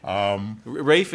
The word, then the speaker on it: Raph: Raph is the, so it's the same name, Raphael that you see around. Raph is the English pronunciation, and it means God heals, and it's after the angel of healing.